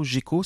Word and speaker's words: Jeko: Gecko, cela s'écrit J-E-K-O, tout simplement. Tiens, par contre, fin, cela s'écrit F-I-N.